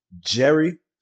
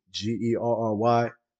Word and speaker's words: Gerry: Yeah, yeah, Gerry with a G, but spell it ghetto. So Gerry obviously spelt with a G, G E R R Y, with W I T T A A G.